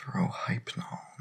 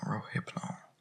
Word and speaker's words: Rohypnol: This Rohypnol, at least pronounce it right. – Excuse me? – It's Rohypnol, not Rohypnol.